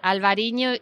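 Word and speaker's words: Albariño: is a grape variety called Albarino. Did I say it correctly? – Yes, Albarino is the...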